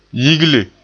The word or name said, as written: yigle